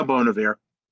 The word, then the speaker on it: Bon Iver: Yeah, I know Bon Iver. – Bon Iver, that's how you say his name.